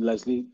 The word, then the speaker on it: Lesléy: So okay, so you can catch me on all streaming platforms with the name Lesléy. The last E is a Beyonce E, so that has that accent. But yeah, Lesléy on all streaming platforms.